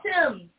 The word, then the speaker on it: Thames: It's the Thames.